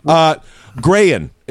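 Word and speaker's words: Grehan: Anyway, Grayan is the way that we've been told to pronounce this handsome fella's last name. Derry Grayan.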